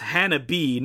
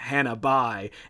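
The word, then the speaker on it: Hana-bi: It's Hannah B, not Hannah Bai, and Will made some statement about the title being more poetic in Japanese.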